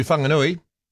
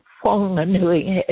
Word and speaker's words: Whanganui: News Talk ZB, here we go to Whanganui. – Whanganui. – Whanganui? – Oh dear. – Whanganui, Whanganui. That's where you're from, though, eh? I've got the city, right? – Yeah. But it's Whanganui, as in what?